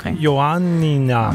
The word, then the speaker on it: Ioannina: Til en by, som jeg tror udtales Ioannia. – Er det ikke tit ret fonetisk, det der sprog? Ioannia? – Ioannina.